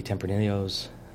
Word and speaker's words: Tempranillo: How do you pronounce it? – Give it to the wine guy over here. – The wine guy. – I say Tempranillo. – Tempranillo.